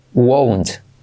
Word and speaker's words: won't: No es como want, como quiero, wawa, como want, want, sino como u o u. Won't, won't.